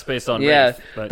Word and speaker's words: Wraith: Wraith. – Ah, Wraith.